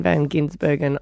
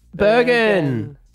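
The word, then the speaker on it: van Gisbergen: And then I glanced at my notes and went, oh, no, Van Ginsburg. Bergen.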